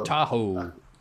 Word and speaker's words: Tahoe: God, and also, is it like Tahoe or something? I can't remember how it's pronounced. Lake Tahoe. – Tahoe.